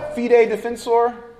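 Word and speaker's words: Fidei Defensor: How do I say that? Fide. I know the translation, but Defensor, is that the right way to pronounce it? Well, Fide Defensor. Okay.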